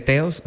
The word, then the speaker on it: Theos: T-C, y de repente dije, ah, Tessa, y dije, como mi nombre se escribe Teos, se escribe T-H-E-O-S,